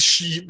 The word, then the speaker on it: Sith: the Sith, Sith, hvordan vi udtaler det? – Jeg, jeg vil sige she, men... Uh... – She, ja. The She,